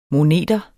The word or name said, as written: moneter